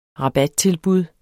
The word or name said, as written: rabattilbud